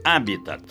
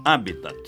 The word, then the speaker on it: habitat: Pronuncie corretamente. Habitat, como proparoxítono, sem esquecer o T mudo final. Habitat.